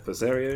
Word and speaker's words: Berseria: Tales of Berseria. I don't know how to pronounce it. – Yeah, Berseria is correct.